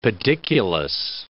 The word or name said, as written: pediculous